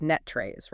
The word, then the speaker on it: NTREIS: And for anyone who doesn't know, that's spelled NTREIS, but pronounced NETREIS, right? Like a word rest, but with a net.